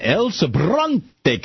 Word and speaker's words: El Sobrante: All right, let's get over to, real quickly, James in El Sobrante, California.